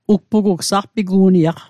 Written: ugpugugw

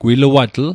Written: gwiluatl

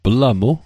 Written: plamu

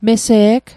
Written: mese'g

